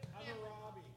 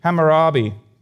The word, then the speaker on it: Hammurabi: We actually see that there's a Babylonian king, which I can't pronounce his name right, but Hammurabi, I don't know. Your guess is as good as... What? How do you say it? – Hammurabi. – Oh, thank you. Hammurabi.